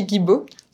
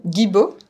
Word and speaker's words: Guibaud: Sophie Guibaud. – Sophie, one more time. – Guibaud. – See, my surname is French, right?